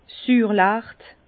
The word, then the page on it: Süürlecht: Bas Rhin; Herrlisheim